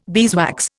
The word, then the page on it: beeswax: Indian